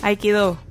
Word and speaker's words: Aikido: Vamos a hablar de Aikido o Aikido. ¿Cómo se dice, eh, ¿Usted eh, Aikido. – Esa es la pronunciación correcta.